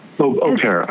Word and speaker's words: au pair: Au Pair.